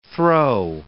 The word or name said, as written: throw